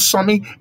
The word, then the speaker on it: Sammi: On your phone, on your iPad, on your laptop, go to the YouTube, put my name there, Successful Sammy, SUCCESFUL. Sammy is spelled S for school, A for apple, N for Nigeria, M for mango, I for ice cream. And once you put it there, Sammi, S for school, A for apple, N for Nigeria, M for mango, I for ice cream.